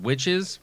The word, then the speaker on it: Wytches: And after that one, I wrote down Witches, but it's spelled W-Y-T-C-H-E-S.